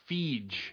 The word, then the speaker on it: Feige: And I've heard it now pronounced Feige.